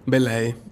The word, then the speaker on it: Bellei: Belay.